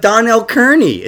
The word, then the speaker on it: Donnell Kearney: I have Donnell Kearney.